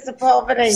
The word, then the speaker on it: Sepulveda: We pronounce it Sepulveda here.